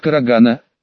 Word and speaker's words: карагана: Читается как Карагана, больше известно под простонародным названием желтая акация.